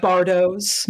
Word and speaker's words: Bardos: Did I say that right? Is it Bardos? Bardos? – Bardos. Yeah, I think okay. That's right.